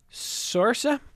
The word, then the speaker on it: Saoirse: Is spelled S-A-O-I-R-S-E. Sorsa Ronan.